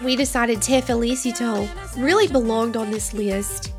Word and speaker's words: Te Felicito: Te Felicito by Shakira. – I'm sorry, you said that wrong. It's Te Felicito by Shakira and Raúl Alejandro.